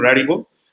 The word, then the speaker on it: Rarible: hay una que se llama Rarival, perdón, Rarible, iba a intentar pronunciarla en español, pero es A-R-I-B-L-E.